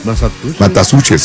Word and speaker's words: Massachusetts: Massachusetts, sí.